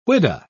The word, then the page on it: whydah: British English